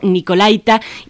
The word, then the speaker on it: Nicolaita: Pues sí, tradicionalmente así le decimos todos, pero, pero nos han avisado desde la misma Radio Nicolaita, que se dice así, así se pronuncia, así se acentúa.